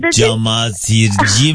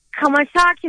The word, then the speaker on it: Çamaşırcı: Çamaşırcı Mehmet. – Evet. Bir anda durdu dedi ki Kamaşaki Mehmet dedi. Bu kadar harcama dedi, nedir dedi. – Evet, hep İngilizce görünce onu da İngilizceye telaffuz etti.